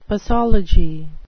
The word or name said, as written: posology